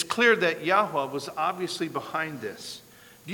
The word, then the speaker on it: Yahweh: God's name, the best we can figure out how to pronounce the Hebrew, is Yahweh.